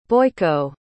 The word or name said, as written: Boyko